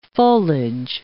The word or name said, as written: foliage